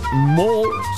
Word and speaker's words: mall: Voor degenen die het Engels niet zo onder de knie hebben, mol spreek je bijna hetzelfde uit als mol.